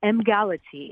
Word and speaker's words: Emgality: And so Doc, again, before we run out of time, so it's pronounced Emgality? – Exactly. It's Emgality.